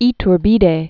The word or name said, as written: Agustin de Iturbide